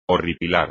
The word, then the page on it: horripilar: espagnol